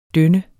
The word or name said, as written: døn